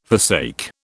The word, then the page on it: forsake: British English